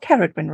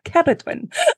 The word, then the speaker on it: Ceridwen: listen to it pronounced in Welsh, everyone. Hopefully, after my training, I'll be pronouncing it in Welsh too. Not Ceridwen, Rebecca, Ceridwen.